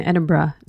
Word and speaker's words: Edinburgh: And we also hear from some humanist university students in Edinburgh, Scotland, about their new campus group. – Nice pronunciation on Edinburgh. A lot of Americans mispronounce it Edinburgh, so good job.